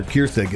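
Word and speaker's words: Kjerstad: Not when the Yankees are laughing in the damn dugout after Kiersted gets hit in the head. Silent J.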